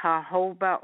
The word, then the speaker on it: jojoba: And I think you pronounce it jojoba oil. Is that a correct pronunciation of that wonderful oil? – Absolutely.